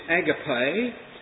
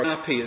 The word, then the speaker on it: agape: The first one, of course, is agape, or api, as the Americans say. Agape, it's a long E sound.